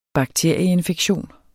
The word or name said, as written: bakterieinfektion